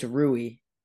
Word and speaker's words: Rui: It's Rui.